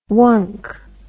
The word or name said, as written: wunk